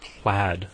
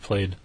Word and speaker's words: plaid: something spelled P-A-I-D is pronounced paid, but you put that L and it's pronounced plaid instead of played? – Right.